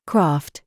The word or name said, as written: PALM